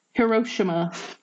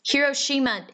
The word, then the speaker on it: Hiroshima: Heroshama. – Heroshima, idiot.